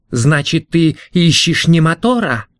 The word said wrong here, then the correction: Минотавр: «Минотавра», — поправил Тисей, следя за точным произношением.